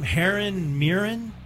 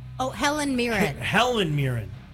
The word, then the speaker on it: Helen Mirren: And then, how do you say her name? – Helen Mirren. – Helen Mirren.